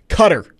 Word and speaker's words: Qatar: Way it's pronounced. Qatar. That is the correct pronunciation.